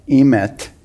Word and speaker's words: emet: you would remove the aleph from the word emet, am I saying it right? Mm-hmm.